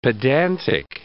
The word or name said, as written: pedantic